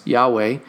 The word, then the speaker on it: Yahweh: The Lord tells him, he says, my name is it's Yahweh. It's actually the Yod Heh Vah Heh, which is the Yod is the Yah. That's how you pronounce it.